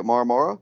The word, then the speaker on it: Marmara: I think is how you say it, Marmara.